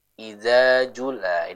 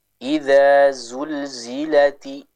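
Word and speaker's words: iza zulzilati (إِذَا زُلْزِلَتِ): Bukan iza jula, ini kurang tepat. Tapi kita ya ucapkan sesuai dengan hurufnya masing-masing. Contoh, iza zulzilati, iza zulzilati, seperti itu.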